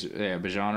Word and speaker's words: Bijan: And Bijan Robinson there, I don't know how to say it. I think it's Bijan Robinson.